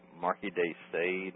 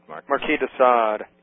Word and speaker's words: Marquis de Sade: Marquez Day, and I may be pronouncing this wrong, but... Marquis de Sade, Marquis de Sade, Marquis de Sade.